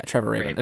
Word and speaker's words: Trevor Rabin: Yeah, Trevor Rabin. As far as I know, that's how you pronounce it.